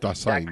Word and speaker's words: Dasein: D-A-S-E-I-N, in this piece, and... I think I'm pronouncing it, at least for myself, Das Sein, but Das Sein.